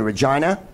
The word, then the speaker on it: Regina: But Who's Line's going to be in Regina, North America's most hilariously named city. Get it? Regina rhymes with something that Americans say.